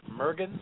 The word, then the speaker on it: Mergens: Celeste Mergens.